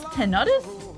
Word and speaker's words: tinnitus: It's pronounced tinnitus? I've been saying it wrong this whole time.